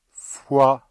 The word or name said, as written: fois